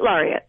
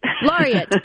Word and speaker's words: Laureate: Oh, it's Poet Laureate. – Laureate. Yay. Okay. I'm sure I'm not the first person to have mispronounced it.